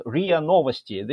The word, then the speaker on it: RIA Novosti: And, before that, I wanted to tell you, I worked at RIA Novosti. This is the way it is pronounced.